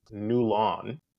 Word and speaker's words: Newlon: If you slow it down, N-E-W-L-O-N, Newland, can also be pronounced New Lawn, as in you just did someone's lawn and it looks great, from Patrick Dempsey's first film that I know of, Can't Buy You Love, where he was a lawnmower boy.